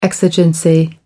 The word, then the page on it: exigency: American English